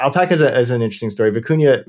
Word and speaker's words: Vicuña: So it was, yeah, Vicuña is the correct pronunciations.